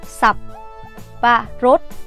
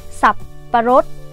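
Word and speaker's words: sập pa rốt (สับปะรด): hai chục bạc một bịch á thơm á là tiếng thái đọc là sập pa rốt nè sập pa rốt sập pa rốt nha sập parrot mình đọc nhanh nhanh một xíu tại vì cái từ này nó dính vô nhau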